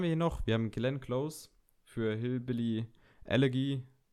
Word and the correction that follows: Elegy: Wird das so ausgesprochen? Allergy. – Allergy. – Elegy, ja.